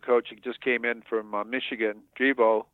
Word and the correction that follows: Drevno: Drevno, is that the way you pronounce it? – With uh, Drevno? – Yeah.